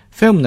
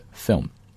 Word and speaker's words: film: Film 呢这个词的发音也可以给大家提一提，在读的时候也是这个 I 字母是一个短音的 I 啊，所以读的时候是 film 不是 film 啊，是 film。